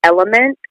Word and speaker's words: LMNT: So you may have your own electrolyte blend powder, but I recommend one called Element, which is spelled L-M-N-T.